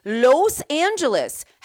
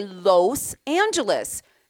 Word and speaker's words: Los Angeles: She's like, it's Los. Los Angeles. How do you spell it? L-O-S. You say Los Feliz, so you should say Los Angeles. And she's like, well, okay, but most people just say Los Angeles. Los. And she's like, no, it's L-O-S.